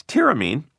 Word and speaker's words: tyramine: Yes, tyramine.